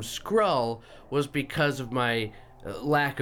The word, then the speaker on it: Skrull: You're saying I could be a Skrull having no knowledge of Skrulls.